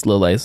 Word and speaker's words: Lillet: I believe it's Lillet since it's French.